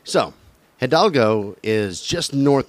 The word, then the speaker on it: Hidalgo: Hidalgo, yeah.